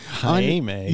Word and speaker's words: Jaime: Jaime. – Yeah, that's how he pronounces it. I love it. It's just Jamie, but Jaime.